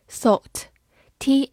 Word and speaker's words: thought: thought，t h o u g h t，thought。 t h 发咬舌音，o u 发 o，g h 不发音，t 发 t，thought，thought，它是一个名词，表示想法、看法或者考虑。